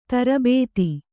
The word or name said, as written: ದುರ್ಬುಧ